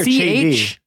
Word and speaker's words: Chaedi: Chady or Chady? – C-H-A-E-D-I. Chady. So I'm going to pronounce it.